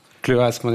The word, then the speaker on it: chleuasme: Parce que je pense que ça se prononce chleuasme, en effet.